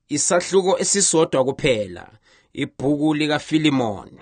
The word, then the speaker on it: Philemon: So, Philemon, someone said back there, which I think is really the correct pronunciation of it.